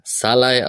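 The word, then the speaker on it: Szalai: Salai, also Nico, äh, Nick hat es im Rasenfund erwähnt, im Gegensatz zu unserem Scholai wird der Salai ausgesprochen mit dem SZ am Anfang.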